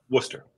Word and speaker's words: Worcester: Did I pronounce it right? – Worcester. – Worcester. Okay.